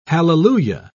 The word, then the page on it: halleluiah: British English